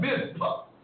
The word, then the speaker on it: Mizpah: The place called Mizpah. M-I-Z. P-A, come on somebody, M-I-Z-P-A-H, I believe is the correct pronunciation.